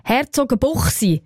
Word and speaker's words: Herzogenbuchsee: Herzogenbuchsee gibt ja so einen Ort. Sagt man selbstverständlich korrekterweise Herzogenbuchsee.